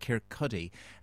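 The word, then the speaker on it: Kirkcaldy: is located in a place called Kirkcuddy.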